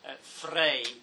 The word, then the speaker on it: Frei: Please note in the pronunciation here when Viviana says Frei, distinct from Freire. Paulo Freire, Brazilian educator, Eduardo Frei Montalva was the Christian Democrat president.